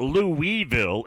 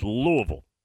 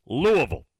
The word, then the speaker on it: Louisville: Again, I implore you, get the pronunciation right. It's not Louisville, it's Louisville. You got to go with the locals, Louisville.